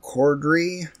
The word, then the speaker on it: Corddry: Stars Rob Corddry, that's how you say his name, Leslie Bibb, who you would recognize if you saw, Keegan-Michael Key.